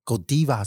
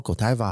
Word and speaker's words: Godiva: 结果查着查着就发现，看他的那个官方脸书竟然有发表官方声明，他说两种发音都可以。 Godiva 是比较偏。比利时原来的发音，还有亚洲人习惯的发音用法，然后 Godiva 好像是英语系国家大家比较习惯念的，等于说他们自己身为品牌方，可能认为你怎么诠释都不算是错的。